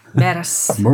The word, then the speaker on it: 메르스: 머스. 자, 메르스. 머스라. 외국 사람들 네. 발음이 머스.